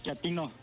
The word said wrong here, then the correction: chatino: No, chatino. – Es como chato, ¿verdad? Chatino. – Sí, azunero, como chato, chatino.